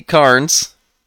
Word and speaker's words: Carnes: We got Anthony Carnes. I said that right. I didn't say the I in Carnes.